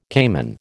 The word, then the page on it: caiman: American English